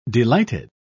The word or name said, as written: delighted